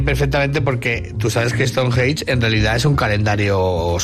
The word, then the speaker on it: Stonehenge: Eres la persona que mejor he escuchado pronunciar Stonehenge. – Stonehenge.